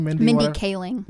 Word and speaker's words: Mindy Kaling: Mindy Kaling. – Mindy Kaling's in the future to be like, hey, this isn't bad.